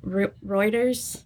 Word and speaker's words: Reuters: I don't want to say Reuters. Is that how you say it? – Uh-huh, okay. It is. I think so. That's how I say it.